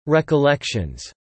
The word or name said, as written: recollections